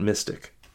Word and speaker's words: MSTIC: I imagine they pronounce that Mystic.